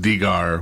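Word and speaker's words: dingir: And then the second symbol looks kind of like a star on purpose and is it's pronounced digar, which is the word for a god or the symbol for a god.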